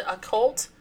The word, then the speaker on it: occult: Is it a cult? O-C-C-U-L-T. – Yeah.